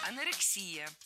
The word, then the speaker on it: анорексия: Анорексия. И здесь все правильно. Ударение ставим на предпоследний слог, на «и». Анорексия.